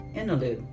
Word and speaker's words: Innaloo: And returning to Australia, the western bit this time, there's a town called Innaloo, which rhymes with place to poo.